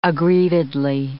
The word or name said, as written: aggrievedly